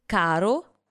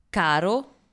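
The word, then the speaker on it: caro: Например, слово «каро» означает «дорогой», а вот слово «карро» с двумя буквами «р» означает «повозка». Слушаем еще раз. – «Каро», «карро». – «Дорогой», «повозка». – «Каро», «карро».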